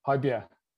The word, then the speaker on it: Højbjerg: Højbjerg. Yeah. How do you pronounce his last name? – Højbjerg. Yeah.